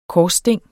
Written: korssting

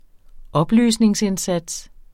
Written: oplysningsindsats

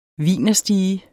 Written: wienerstige

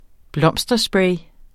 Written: blomsterspray

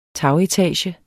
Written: tagetage

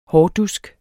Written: hårdusk

